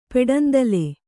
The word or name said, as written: ಪೆಡಂದಲೆ